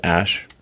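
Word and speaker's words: Æ: I mean, it's just X, the letter X, and then the A-E is like pronounced Ash, and then A-12 is my contribution.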